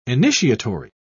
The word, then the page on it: initiatory: British English